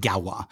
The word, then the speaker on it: Gauloises: But then I started looking at the I forget how you spell these. The Galwa. He says it in the audiobook. He, he pronounces it Galwa. It's G A U L O I S E S. Pronounced Galwa or Gauloises.